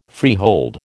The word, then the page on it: freehold: American English